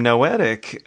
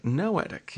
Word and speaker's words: Noetic: But you have such an interesting company name, Noetic. Am I saying that correctly? – Yes. Cool. Rhymes with poetic. – Poetic. Noetic. Yeah,